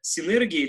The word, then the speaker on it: синергия: У нас уже такая синергия или синергия, все время забываю, как правильно ударение ставить. Синергия.